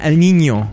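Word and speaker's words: El Niño: Yeah, El Nino.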